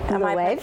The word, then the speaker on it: Loewe: Uh, Loewe. Loewe, yes, yes, good.